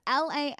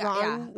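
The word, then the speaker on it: Laneige: Is it Lajans or no? – It's Lange. – I think Lange.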